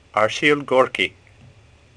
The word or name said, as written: Gorky